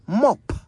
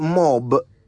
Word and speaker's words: mop: Mop. M-O-P. Mop. A simple enough word, I would have thought, yet it would amaze you just how often people say it wrong. It's not mob, it's mop.